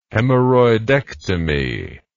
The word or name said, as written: hemorrhoidectomy